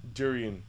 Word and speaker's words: durian: There was this one bag nobody ate, and what it was taffy, but it was made from the, am I saying it right? Durian. – Durian.